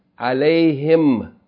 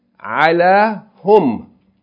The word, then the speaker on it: alayhim: you pronounce, that's why you say, alayhim. Originally it's ala hum.